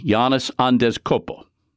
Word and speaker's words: Giannis Antetokounmpo: The Greek freak in Milwaukee, Giannis Antetokounmpo.